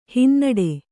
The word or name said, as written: ಹಿನ್ನಡೆ